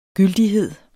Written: gyldighed

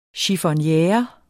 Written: chiffonniere

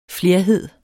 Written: FLERHEDERNES

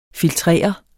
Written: filtrere

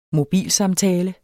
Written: mobilsamtale

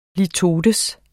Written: LITOTER

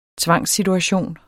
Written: tvangssituation

